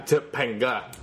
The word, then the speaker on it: Topanga: Topanga? – Yeah, Topanga. – You know three syllables.